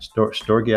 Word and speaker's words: storge: Storge, I think. – Storge, it's S-T-O-R-G-E, right.